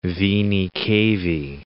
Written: venae cavae

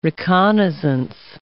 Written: reconnaissance

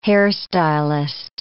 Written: hairstylist

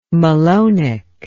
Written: malonic

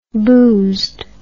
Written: boozed

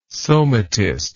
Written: somatist

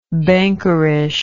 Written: bankerish

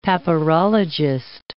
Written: papyrologist

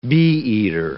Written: bee-eater

